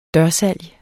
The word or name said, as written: dørsalg